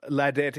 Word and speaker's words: Ladette: Tamika has said Ladette is pronounced as lad, rhymes with tad.